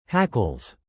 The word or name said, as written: hackles